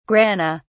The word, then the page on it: grana: British English